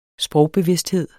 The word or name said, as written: sprogbevidsthed